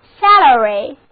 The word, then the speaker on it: salary: 我们在表示盐的词根 SAL 之后加上 ary 的名词后缀，那么我们再次回到古代希腊的斯巴达城邦，我们看到盐就是军饷，所以 salary salary 前一个 a 字母发 a 的梅花音，后一个 a 字母发作 a，单词就读作 salary，就是工资薪酬，尤其指非体力劳动者按月领取的报酬。